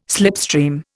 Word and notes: slipstream: Indian

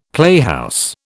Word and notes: playhouse: British English

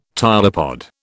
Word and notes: tylopod: British English

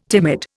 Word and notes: timid: Indian

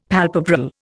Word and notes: palpebral: Indian

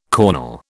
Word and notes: cornel: British English

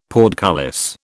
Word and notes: portcullis: British English

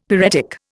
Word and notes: pyretic: Indian

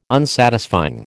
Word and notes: Unsatisfying: American English

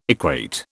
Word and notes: equate: British English

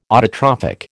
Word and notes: autotrophic: American English

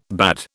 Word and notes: bade: British English

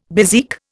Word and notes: bezique: Indian

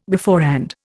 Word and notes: beforehand: Indian

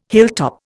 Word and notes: hilltop: Indian

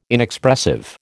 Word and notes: Inexpressive: American English

